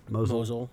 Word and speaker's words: Mosul: Mosul, yeah.